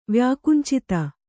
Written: ವ್ಯಾಕುಂಚಿತ

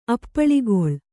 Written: ಅಪ್ಪಳಿಗೊಳ್

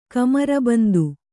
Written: ಕಮರಬಂದು

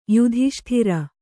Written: ಯುಧಿಷ್ಠಿರ

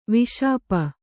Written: ವಾಷ್ಪ

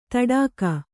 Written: ತಡಾಗ

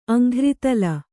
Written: ಅಂಘ್ರಿತಲ

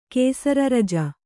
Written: ಕೇಸರರಜ

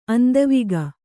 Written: ಅಂದವಿಗ

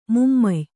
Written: ಮಾಮಾಯೆ

